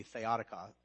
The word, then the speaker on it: theotokos: Now, what kicks this off is he hears this phrase, theotokos, which means God's tacos. No, it doesn't mean that. I think the correct way to pronounce it would be theotokos, which means God bearer, God birther, or the one who brings forth God.